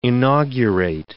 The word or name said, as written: inaugurate